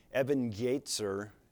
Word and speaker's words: Ebenezer: Ebenezer is an approximate Hebrew pronunciation.